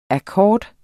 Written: akkord

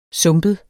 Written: sumpet

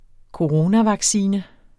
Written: coronavaccine

